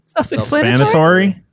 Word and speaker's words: self-explanatory: Crump, all elves good except the one on the shelf and s'mores shelf expiatory. Did I say that right? Probably not. – Self-explanatory.